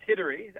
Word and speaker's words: Hidari: But before we get into stress and how you help people with stress, who is, if I'm pronouncing your name right, who is Murri Hadari? – Yeah, it's Hidari, but no problem.